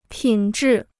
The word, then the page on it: 品质: pǐn zhì